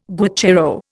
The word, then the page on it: guacharo: Indian